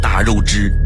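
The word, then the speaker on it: 大月氏: 汉武帝决定找的合作伙伴是一个叫做大肉汁的国家。这个大肉汁是什么样的国家呢？大是大小的大，肉是月亮的月，汁是姓氏的氏。有兴趣的朋友可以查一下，这个在古代读作大肉汁，是一个国家名。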